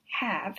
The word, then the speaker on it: have: Some other possibilities are give, G-I-V-E, love, L-O-V-E, glove, G-L-O-V-E, come, C-O-M-E, and done, D-O-N-E. Like have, these words are all similar in that the final E is not pronounced, but the vowel in each of them is short, not long.